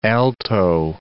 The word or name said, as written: alto